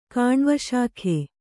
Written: ಕಾಣ್ವಶಾಖೆ

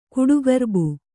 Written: ಕಡುಗೊರ್ಬು

